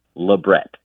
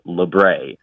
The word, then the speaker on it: labret: Most piercers will insist that the word is pronounced labret with a T at the end, the hard T. But some other people will say, well, the word came through France, so it's labret.